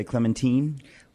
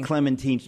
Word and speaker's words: Clementine: We say Clementine? – We should say. She liked it. – She liked Clementine. We get to choose how people pronounce our names.